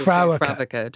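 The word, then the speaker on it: Fréwaka: How would you say it? – I would say Frey Wacha. Some people say Frey Wacha, depending on your dialect.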